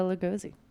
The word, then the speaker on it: Lugosi: All right, let's talk about that other guy, Bela Lugosi.